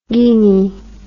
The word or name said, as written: guinney